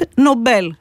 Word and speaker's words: Nobel: Στη χώρα του είναι Alfred Nobel στα σουηδικά.